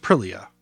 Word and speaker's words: Aprilia: I want to remind everybody that there's only one L in Aprilia. I hear a lot of people, again, they think it should be pronounced more exotic than it is. And they want to say Aprilia. And it's like, no, it's just Aprilia.